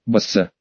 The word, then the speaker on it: Басса: И звучное такое, Басса. Ударение на первый слог? Да, на первый.